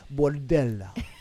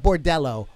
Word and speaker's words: Bordella: I feel like I should say Bordella. it's not Bordello, Bordella.